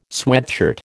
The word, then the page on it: sweatshirt: American English